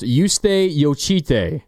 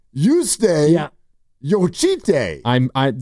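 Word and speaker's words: Jočytė: Her name is pronounced Yuste Yochite. 19-year-old, 6-foot guard from Lithuania. – I like the player, and I like the pick. – I really like the name. Yuste Yochite. – That's how the WNBA commissioner pronounced it. So. – Okay. – Yep. – And it's the first name is spelled J-U-S-T-E.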